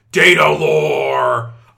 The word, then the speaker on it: Datalore: I mispronounced the first one intentionally. I believe the correct pronunciation is Datalore. Datalore! I am Datalore.